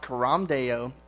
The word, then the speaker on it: Coram Deo: It's actually Latin, and like I said, it's pronounced Coram Deo, and basically it literally means before God.